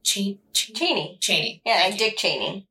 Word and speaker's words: Chaney: What's really cool is that the actor Lon Chaney, I want to say this is his last name. I hope I'm pronouncing that right. – I think most people say Chaney. – Chaney.